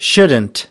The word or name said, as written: shouldn't